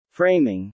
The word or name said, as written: framing